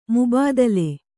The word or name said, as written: ಮುಬಾದಿಲೆ